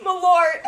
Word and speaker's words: Malort: How do you say it? Marlowe. – Oh my God! – What is it? – Malort. – Malort. That's what it is.